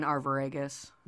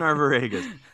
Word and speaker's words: Arviragus: But the most frequently cited examples is like the prominence of homoeroticism throughout the play with Gerdius and how do you say their names? – Guiderius and Arviragus. – Them. Guiderius and Arviragus.